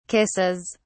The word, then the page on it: kisses: British English